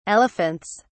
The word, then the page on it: elephants: British English